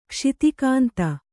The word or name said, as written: ಕ್ಷಿತಿಕಾಂತ